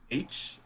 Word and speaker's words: Aitch: His name is Aitch. Uh, it's spelled like A I T C Aitch.